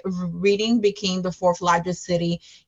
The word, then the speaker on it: Reading: A lot of my kids were telling me Reading, Reading, or oh, it's pronounced reading, um, like reading.